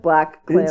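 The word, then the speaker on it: BlacKkKlansman: It's, how do we say it? – Well, I think it's just Black Klansman. – It's a visual pun, really.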